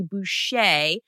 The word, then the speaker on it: Bucher: My last name is actually Bucher. – I butchered Bucher or Bucher. – Well, I like to tell people, I'm sure you're familiar with the water boy.